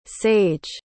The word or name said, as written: saige